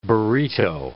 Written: burrito

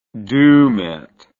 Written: dumet

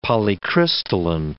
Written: polycrystalline

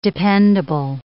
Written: dependable

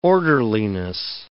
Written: orderliness

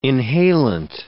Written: inhalant

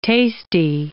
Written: tasty

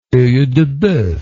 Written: oeildeboeuf

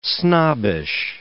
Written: snobbish

